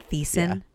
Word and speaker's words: Thiessen: Is that how you say it? Thiessen? – Yeah.